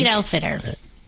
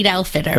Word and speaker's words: Eid al-Fitr: All right, and so then there's also today something, help me with the pronunciation of this, if you will, Eid al-Fitr, I think. – Eid al-Fitr. – Eid al-Fitr, is that how you pronounce it? – Eid rhymes with feet. Al-Fitr. Yeah, Eid al-Fitr.